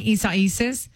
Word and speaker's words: Isaias: Is Isaias. Am I saying that right? Isaias. Yeah, I don't know who names these hurricanes either.